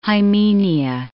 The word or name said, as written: hymenia